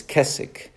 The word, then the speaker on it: Keswick: I know there's a W there. The W is silent. Kind of like K in knife. It's not knife. It's knife. It's Keswick.